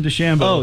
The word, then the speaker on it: DeChambeau: No, it's Bryson DeChambeau. – Oh, DeChambeau. – Stop pronouncing it like that.